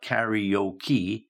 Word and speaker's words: karaoke: I understand what you're saying, but I think it's actually pronounced karaoke. Yeah. Not karaoke. Yeah, it's karaoke. Yeah, not karaoke, it's karaoke.